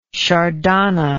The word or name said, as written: shardana